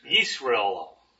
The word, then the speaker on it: Yisrael: And the second word sounds pretty much like you would think, Israel, okay? Shema, Yisrael, okay? – Israel.